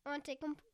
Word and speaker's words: Antetokounmpo: There's silent letters. It's Antetokounmpo.